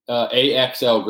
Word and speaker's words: Axl: AXL Graybeal, which is my real middle name for people that are curious, but.